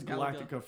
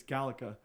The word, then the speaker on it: Galaga: I thought it was Galactica for the longest time, but it's Galaga. – Mm-hmm. – And I always called it Galaga.